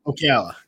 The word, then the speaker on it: Ocala: A 53-year-old woman by the name of Tina Kindred got wasted, ended up at an Outback Steakhouse in Ocala. Is that how you say it? Florida? – Ocala.